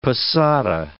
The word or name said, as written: posada